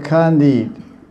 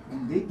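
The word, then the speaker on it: Khandita: the beloved Radharani assumes the mood of Kandit. Kandita. What? Kandit. – Otherwise, we don't hear the T at the end. Kandita. – Yeah, it's a long A there, yeah. Kandita.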